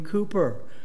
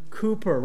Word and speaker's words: Cowper: I mean, this is stuff that people like William Cooper, pronounced sometimes Cowper, William Cooper, right, the hymn writer.